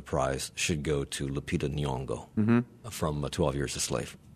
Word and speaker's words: Nyong'o: are Sally Hawking for Blue Jasmine, Jennifer Lawrence in American Hustle, Lupita Nyong'o, is that how you say her name? – Mm-hmm.